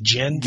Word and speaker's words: Jyn: Is that how you pronounce her name? J-Y-N? Is it Jin? – Yes.